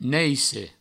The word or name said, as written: naisse